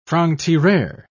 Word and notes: franc-tireur: British English

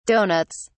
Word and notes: donuts: British English

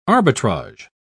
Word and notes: arbitrager: British English

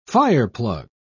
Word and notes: fireplug: British English

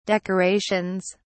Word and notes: decorations: British English

